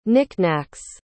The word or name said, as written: knickknacks